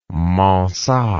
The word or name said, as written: mansart